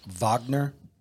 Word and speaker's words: Wagner: How the Russian mercenary group, it's spelled Wagner, but pronounced Wagner, it's Putin's private army, was behind the coup in Niger.